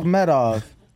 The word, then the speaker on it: Akhmedov: Where do you see the L at, bro? – I don't know. – Stop adding it. Oh. Akhmedov.